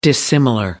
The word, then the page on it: dissimilar: American English